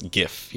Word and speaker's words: GIF: I had a layover Dubai recently I've gotten into small arguments with people over the years about, no, it's GIF, you know, it's a hard G.